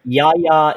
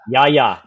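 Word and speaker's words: Jaja: And that's J-A-J-A Ding Dong. – Wait, it's J-A-J-A? – Yeah, Ya Ya, because It's a soft J. – It's a soft J. It's Icelandic soft J.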